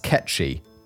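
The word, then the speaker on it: cat-sìth: Oh, apparently it's pronounced ketchy. Cat cider is how it's spelled, but apparently it's pronounced ketchy because Gaelic.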